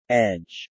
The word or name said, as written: edge